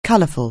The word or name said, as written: colourful